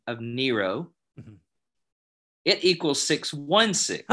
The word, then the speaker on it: Nero: His name in Latin is pronounced Nero. – Yes. – His name in Greek is said differently. It's Neron.